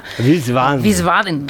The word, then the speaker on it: Wiesbaden: Sabéis que, a ver si lo digo bien, Wiesbaden en Alemania. – Wiesbaden. – Todo lo dices bien, querida amiga. Muy bien lo dices.